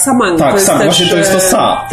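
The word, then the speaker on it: sa (사): Numer 4 po koreańsku, wymawiany jako sa, znaczy to samo, co śmierć, które po chińsku, w znakach chińskich, jest również wymawiane jako sa.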